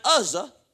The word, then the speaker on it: Uzzah: Uzzah.